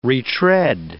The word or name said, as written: retread